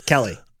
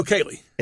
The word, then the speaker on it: Kaley: Yes, Kelly. – No, Kaylee. – Is it Kaylee? Okay. – Well, it's spelled K-A-L-E-Y.